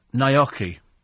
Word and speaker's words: gnocchi: Giulietta says, being half Italian, I feel it's my duty to advise you they're pronounced gnocchi, rhyming with hockey and a yummy.